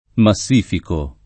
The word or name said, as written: massificare